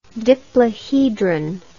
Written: diplohedron

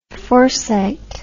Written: forcite

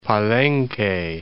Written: Palenque

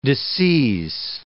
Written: disseize

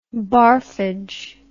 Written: barfage

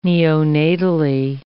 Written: neonatally

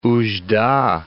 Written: Oudjda

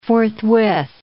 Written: forthwith

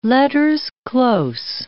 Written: letters close

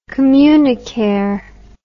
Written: communicare

